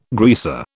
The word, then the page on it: greaser: British English